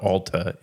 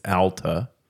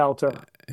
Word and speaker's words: Alta: I understand it's not Alta, it's Alta. – Correct. That's how they say it, Alta.